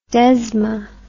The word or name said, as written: desma